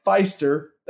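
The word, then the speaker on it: Pfiester: We got Orville Overall, Jack Feister. That's PF. So there's a silent P at the beginning of that.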